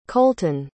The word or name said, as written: colten